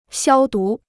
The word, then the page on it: 消毒: xiāo dú